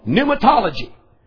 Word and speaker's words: pneumatology: The next word, and you ought to get this one pretty easy, and it's the word called pneumatology. And you spell it by P-N-E-U, not pneumatology. It starts with a P. P-N-E-U-M-A-T-O-L-O-G-Y. P-N-E-U-M-A-T-O-L-O-G-Y. That starts kind of like the word pneumonia.